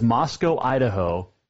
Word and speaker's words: Moscow: And it is Moscow, Idaho, but Moscow, Russia. Remember that, okay? They're spelled the same, pronounced different. Moscow, Russia, Moscow, Idaho. It rhymes.